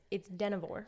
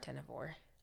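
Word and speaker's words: Dynevor: It's Denevore. – Denevore.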